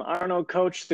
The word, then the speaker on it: Arno Koch: I'm Arno Koch, the coach.